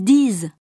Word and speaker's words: disent: E-N-T. – Sans, lent. Mentir, intelligent, récent, fréquent, une dent, l'argent. – Attention, rappel, la troisième personne du pluriel des verbes se termine le plus souvent par ENT, mais cela ne se prononce jamais. Exemple. – Ils disent, ils boivent.